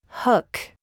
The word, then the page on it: foot: American English